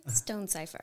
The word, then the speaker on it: Stonesifer: Oh, Kristin Stonesifer.